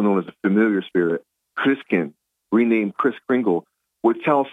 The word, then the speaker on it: Christkind: It comes from the German word Christkind, spelled K-I-N-D, but it's pronounced Kind, Christkind.